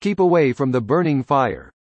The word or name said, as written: burn